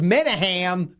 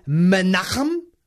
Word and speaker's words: Menachem: I thought his name was Menahem Golan. – You're right. – It is Menachem Golan.